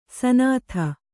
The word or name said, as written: ಸುನೀತ